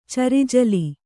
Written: ಚರಿಜಲಿ

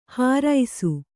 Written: ಹರುಸ

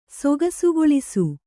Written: ಸೊಗಸುಗೊಳಿಸು

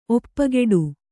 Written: ಒಪ್ಪಗೊಡು

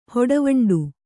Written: ಹೊಡವಂಡು

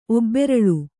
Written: ಒಬ್ಬೆರಲ್

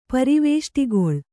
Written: ಪರಿವೇಷ್ಟಿಗೊಳ್

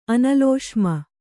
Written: ಅನಲೋಷ್ಮ

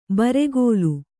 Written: ಬುರುಗಲು

